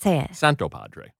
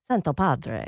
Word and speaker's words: Santopadre: No, I don't say the hard T. – Say it. – Santopadre. – Santopadre.